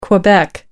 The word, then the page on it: quebec: American English